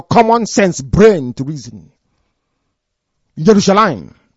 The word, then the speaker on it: Yerushalayim: There is no J in Hebrew pronunciation. It is Y. The same way that Jerusalem is not Jerusalem. It is Yerushalayim, not Jerusalem. There's nothing like J in Hebrew.